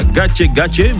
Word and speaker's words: Gake Gake: Oui, ça se prononce comme ça, Gache Gache, mais ça s'écrit Gake Gake.